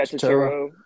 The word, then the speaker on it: Turturro: Is that how you pronounce his name, his last name? – Yeah, Turturro.